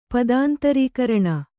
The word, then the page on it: ಪದಾಂತರೀಕರಣ: Kannada